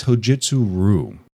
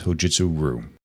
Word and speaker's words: Hojutsu Ryu: It's Hojutsu Ryu, and I knew this, and I can't believe that I actually mispronounced it. I was reading it, and it's spelled R-Y-U, and I had a complete brain meltdown and pronounced it Ryu several times.